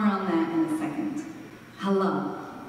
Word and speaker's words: halal: It's spelled H-A-L-A-L, halal. You would see it like H-U-H-L-A-A-L with the emphasis on the last part, so halal.